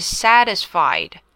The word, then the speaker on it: satisfied: 第三个要注意到的是 satisfied, satisfied. T 我们把它念成 d 的音。